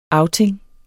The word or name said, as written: outing